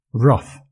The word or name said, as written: rough